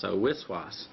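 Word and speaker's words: waswas: There's a term roughly pronounced wiswas. Wiswas sounds like whispering.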